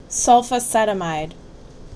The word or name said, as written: Sulfacetamide